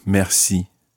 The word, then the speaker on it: merci: Ça va bien, merci. Ça va bien, merci. Try that French R sound. Merci. Merci. And it's pronounced slightly differently depending on which part of the French-speaking world that you're in.